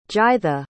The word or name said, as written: Gytha